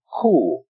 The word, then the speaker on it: chuwl: It's spelled in its pronunciation C-H-U-W-L, but here's the way it would sound. Cool.